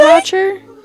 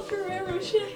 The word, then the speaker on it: Ferrero Rocher: It's called Ferrero Rocher. – Ferrero Rocher? – It's pronounced Ferrero Rocher. What did you just call it? – It's not. – Say it again. – Pharaoh Rocher. – What kind of backwoods pronunciation is that? – There's no way that it's Ferrero Rocher.